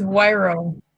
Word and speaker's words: Wyro: It's Wyro. – Wyro. I mean, just like it's spelled, W-Y-R-O.